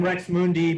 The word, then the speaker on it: Rx Mundi: Yes, uh, I go I go by the name Rex Mundi, but it's spelled R X space M U N D I.